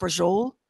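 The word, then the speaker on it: braciole: But it's pronounced brujol.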